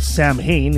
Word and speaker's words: Samhain: Although it's spelled Samhain, it's actually pronounced Samhain.